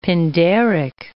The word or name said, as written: Pindaric